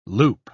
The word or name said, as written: loupe